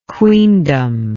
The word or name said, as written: queendom